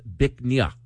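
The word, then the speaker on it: Bích Nga: It's spelled B-I-C-H-N-G-A. It's pronounced Bích Nha.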